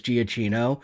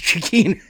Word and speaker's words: Giacchino: That's how I've always mispronounced it, is Giacchino. It's Giacchino.